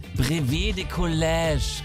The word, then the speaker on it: Brevet de Collège: Und da gucken wir jetzt ganz kurz mal auf ein 9-jähriges Mädchen aus Frankreich, die gerade das Brevet de Collège geschrieben hat. Ich glaube, das spricht man so aus, oder Caro? – Das hast du sehr schön gemacht.